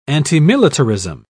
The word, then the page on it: antimilitarism: British English